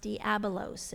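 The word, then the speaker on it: diabolos (διάβολος): It's a compound word in the Greek that you pronounce diabolos, and you can read what dia and belos mean.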